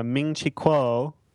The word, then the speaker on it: Ming-Chi Kuo: So yeah, Ming-Chi Kuo.